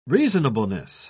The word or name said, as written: reasonableness